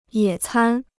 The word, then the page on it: 野餐: yě cān